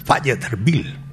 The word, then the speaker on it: Fayetteville: Así se pronuncia, Fayetteville,